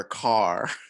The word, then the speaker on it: Kerr: Deborah Carr.